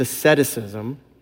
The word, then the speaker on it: asceticism: Asceticism, which the first service I said asceticism, and I only had like 38 people tell me, that's not how you pronounce that. It's asceticism. I'm so sorry to anybody who heard me say it wrong.